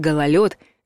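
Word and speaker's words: гололёд: А гололед — здесь «ё» абсолютно законно.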